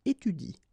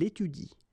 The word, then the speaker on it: étudie: So after another vowel, like here, for instance, you don't pronounce this E, okay? So in that case, if you want to pronounce it correctly, you should pronounce it like il étudie. Okay, so the last thing that you will pronounce will be the vowel I here, okay, il étudie. You don't pronounce this here.